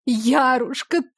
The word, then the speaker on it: Ярушка: Радость-то какая!» Схватив мою безвольную ладонь, женщина припала к ней губами и расплакалась, обращаясь ласково, как только мама называла, с ударением на первую букву. «Ярушка, ты ли это?